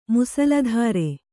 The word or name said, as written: ಮುಸಲಧಾರೆ